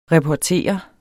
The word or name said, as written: reportere